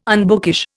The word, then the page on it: unbookish: Indian